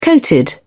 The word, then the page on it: coated: British English